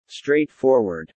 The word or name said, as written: straightforward